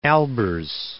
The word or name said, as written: Albers